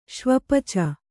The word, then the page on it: ಶ್ವಪಚ: śvapaca